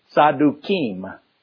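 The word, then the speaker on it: Tzadukim (צדוקים): Now, the plural of Zadok, Z-A-D-O-K, we say in English, is Zadokim. And the way you pronounce that more correctly in Hebrew is Tzadukim.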